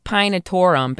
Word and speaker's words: pinetorum: But it's like pinetorum, basically. – Yeah. – P-I-N-E-T-O, accent there. – Accent. – R-U-M. – Yeah, so that's why I would say penitorum. Penitorum. – Well, the meaningful part is the first four letters are P-I-N-E.